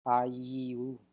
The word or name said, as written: Ayiou